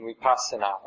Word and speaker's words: vipassana: Samatha and vipassana are the way you pronounce the Pali word.